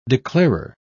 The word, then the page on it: declarer: British English